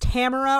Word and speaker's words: Tamara: Tamara, I think it's pronounced Tamara, or at least he pronounces it that way, because yeah. – I remember thinking it sounds like tomorrow.